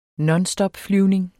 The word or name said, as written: nonstopflyvning